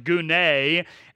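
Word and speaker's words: gune (γυνή): It's actually a Greek word, and it's pronounced gune, and it's this word where we get the word gynecologist, and it is actually a word of endearment.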